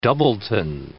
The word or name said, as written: doubleton